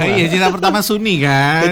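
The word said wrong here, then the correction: Sony: Oh, enggak, memang gitu tulisannya, tapi bacanya Sani.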